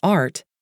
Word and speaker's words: ART: The revolutionary pain relief technique presented here is called Anxiety Relief Technique, abbreviated as ART and pronounced as three individual letters, A-R-T.